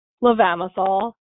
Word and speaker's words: Levamisole: Levamisole? – Levamisole. – Levamisole.